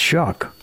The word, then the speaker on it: Shough: I mean, you look at his name, it's spelled S-H-O-U-G-H. That doesn't look like it would be pronounced Shuck.